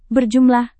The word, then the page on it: berjumlah: Indonesian